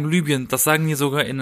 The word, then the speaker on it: Libyen: Aber das ist ja wie, wie wenn man sagt, äh, äh, äh Also korrekt ausgesprochen ja. würde es Libyen heißen.